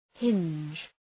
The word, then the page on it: hinge: hındʒ